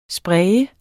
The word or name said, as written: spraye